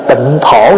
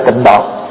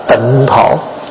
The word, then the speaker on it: tịnh thổ: Thật ra người Việt mình đọc là độ, chứ đúng ra phải đọc là thổ. Tại vì chữ tàu khi mà viết á, là tịnh thổ chứ không phải tịnh độ.